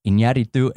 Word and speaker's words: Iñárritu: And that allows you to do all this incredible stuff visually, which Iñárritu and Chivo do so well.